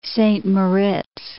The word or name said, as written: Saint Moritz